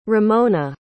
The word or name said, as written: ramona